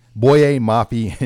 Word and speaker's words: Boye Mafe: Boye Mafe and Thomas Rush,